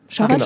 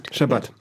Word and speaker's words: Schabbat: Schabbat spricht man es aus? – Schabbat. – Schabbat, auf der zweiten Silbe betont.